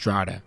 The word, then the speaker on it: Drogheda: And an even more wonderful place is the city of Drogheda, even though it's spelled Drogheda, but it's pronounced Drogheda.